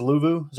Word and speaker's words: Luvu: I think it's Luvu. Is that how you say it? Luvu? – Yeah.